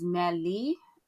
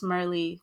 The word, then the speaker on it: Merli: The actual pronunciation is Merli, but no one really can say that. So it's just Merli because that seems easier for most people.